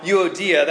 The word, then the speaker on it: Euodia: So here's this gal, Euodia, that's how you pronounce her name.